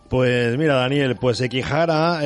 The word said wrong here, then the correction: Sekigahara: eh, que lo he dicho mal, nos cuenta una historia de guerra, honor y amor y que tiene lugar en la famosa batalla de Sekigahara, en el año 1600, un gran conflicto en el que se enfrentaban las dos principales facciones y ejércitos del Japón.